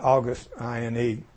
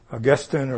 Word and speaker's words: Augustine: A man named Augustine, you spell it August, I-N-E, Augustine or Augustine. But around 400, there's a man came along, his name was Augustine. And I've heard seminary professors pronounce it either way, but I think the more refined say Augustine. Not Augustine. Okay.